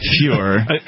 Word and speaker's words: Feuer: It's spelled F-E-U-E-R. Fuhrer. – Are you sure it's Mike and not mine? – Yeah. Fuhrer.